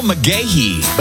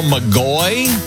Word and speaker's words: McGahey: Oklahoma's country favorites, 96.1 KXY, with a fella whose name is Neil McGahey. But if you look at it, kind of looks like it says Neil McGoy with a G. Yeah, if you didn't know how to say it right. People weren't saying it right.